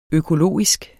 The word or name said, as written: økologisk